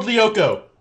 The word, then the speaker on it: Lyoko: Code Lyoko.